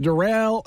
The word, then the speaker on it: Darrell: So Darrell Baker Jr. is spelled like Daryl, but pronounced Darrell.